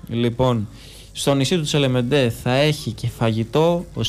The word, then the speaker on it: Τσελεμεντέ: Στο νησί του Τσελεμέντε θα έχει φαγητό. – Τσελεμεντέ, συγγνώμη. – Τσελεμεντέ, γαλλικά θα το τονίζεις.